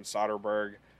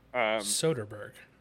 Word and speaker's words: Soderbergh: But Ocean's 11 came out in 2001, directed by Steven Soderbergh. Maybe one of the most underrated directors. I feel like he doesn't get talked about a lot. – Soderbergh.